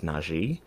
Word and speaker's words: Najee: So my real name is Najee, and it is spelled NAJEE, but if I say it out, if I say it, people say, oh, NAGEE or NAGI or NAJI.